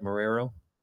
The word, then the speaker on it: Marrero: Is that right, Marrero? – Yes, Marrero.